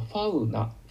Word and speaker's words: fauna: ファウナ、ファウナ、 F-A-U-N-A、ファウナ、動物層、動物に相談の層と書いて動物層ですね。